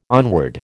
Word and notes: onward: American English

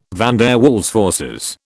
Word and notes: van der Waals forces: British English

